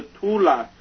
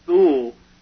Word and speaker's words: Thule: Thule Society, sometimes pronounced Thule in English, which is another occult secret society, as are the Masons.